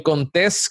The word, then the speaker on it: Contés: Así que mi nombre es Miguel Contés con acento en la E y nos vemos en la próxima.